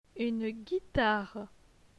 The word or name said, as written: Une guitare